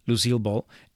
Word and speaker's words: Lucille Ball: Y de hecho, Nicole Kidman como Lucille Ball, ahora sí lo dije bien, Lucille Ball, ella tiene mucho maquillaje, muchas plastas y logró hacer lo imposible.